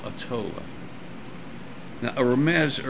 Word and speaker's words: Remez: In Jewish hermeneutics, there's a hidden message or a deeper meaning, which is called, the word is spelled R-E-M-E-Z, I'm just going to pronounce it, Remez.